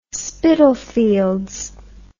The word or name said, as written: Spitalfields